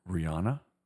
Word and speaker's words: Rihanna: Rihanna? – Yes.